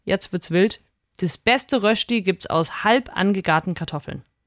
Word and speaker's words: Rösti: Wir machen, aufpassen, die lieben Schweizer, ich kann das richtig aussprechen, die Rösti.